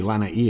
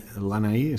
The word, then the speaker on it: Lanai: Okay, so I think you've described, I think it's actually Lanai, isn't it? – Lanai. – So we're saying it wrong.